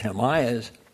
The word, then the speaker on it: Himalayas: And on that whole topic right now, when you go to the Himalayas, the proper pronunciation is Himalayas, the glaciers there are melting faster.